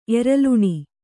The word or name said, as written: ಎರಲುಣಿ